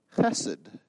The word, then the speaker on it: chesed: It's that word chesed. Everybody say that with me. Chesed. Okay, you kind of got to go, it's a guttural. You got to go from your throat to say it properly.